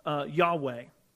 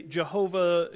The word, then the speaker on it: Yahweh: We pronounce that as Yahweh. The others will say Jehovah, Yehovah. If you mispronounce it, I'm not going to make a big deal out of it, but it's the name of God.